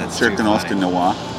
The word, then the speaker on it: noir: It was right after World War II, I believe, when they started making intense film noir.